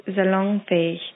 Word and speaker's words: salonfähig: The proper pronunciation for that word is salonfähig.